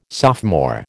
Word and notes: sophomore: American English

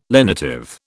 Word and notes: lenitive: British English

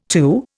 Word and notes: TU: Indian

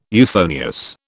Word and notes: euphonious: British English

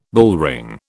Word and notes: bullring: British English

